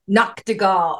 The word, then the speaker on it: Nachtigal: Nachtigal.